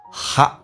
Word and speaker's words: hot: put them in 后面还有个连音 min，用降调来发，红色的字体 put them in hot water， hot water，美式发音这个 hot 比较夸张一点，而且 t 不发音，这属于略音，但是它气流要阻塞一下，很多人不懂得阻塞就听得有点迷茫，叫做 hot，阻塞不发音 hot， hot water。